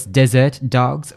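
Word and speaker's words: Derzert Dogz: I'm pretty sure it's Desert Dogs, but it's spelled very uniquely with a D-E-R-Z-E-R-T. And Dogs is, of course, D-O-G-Z.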